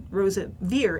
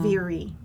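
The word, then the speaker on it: Roseveare: and it said Rosa Veer, as in deer, not Veery.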